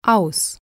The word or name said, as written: aus